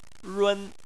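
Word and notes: run: tone 1

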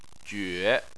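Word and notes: jue: tone 3

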